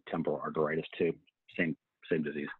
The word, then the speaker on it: arteritis: Giant cell arteritis? – Uh-huh. Arteritis.